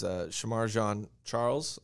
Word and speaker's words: Shamar Jean Charles: One of the surprises for me was Shamar Jean Charles. I believe I'm pronouncing that right. – Yeah, you did a good job. That was good.